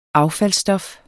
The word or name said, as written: AFFALDSSTOFFER